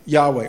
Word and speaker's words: Yahweh: Yahweh, or Jehovah, as we might pronounce it as well.